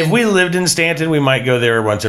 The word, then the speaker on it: Staunton: We went to Staunton, Virginia, which is spelled like it should be Staunton, but it's not. It's Staunton.